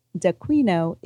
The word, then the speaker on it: Daquino: Debbie is D-E-B-B-I-E. Dequino is D-A-Q-U-I-N-O.